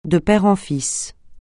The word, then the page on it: de père en fils: francais